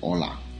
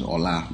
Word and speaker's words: Ola: Ola. My name is Ola. My name is it's O L A. Anyhow you want to pronounce it, it's it's all good.